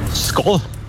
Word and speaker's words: Skål: Skål. – Wow. Richtig? Echt? Ja. Oh. Du hast sogar richtig betont. Oh. Wird Skål geschrieben, Skål aber ausgesprochen.